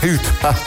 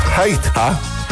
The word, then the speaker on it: Ceuta: Hoe spreek je Ceuta uit? Daar gaan we dan. Oh, heb ik het allemaal weer verkeerd? Oh nee, kijk, daar hebben we het. In het Spaans zeg je... Ceuta. Ceuta.